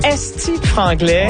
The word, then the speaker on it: Esti franglais: Esti franglais. Ouais. Est-ce que je le dis bien? – Oui, très bien.